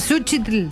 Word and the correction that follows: Cempasúchil: Por ahí vas, pero no se dice así, flaca. – Cempasúchil.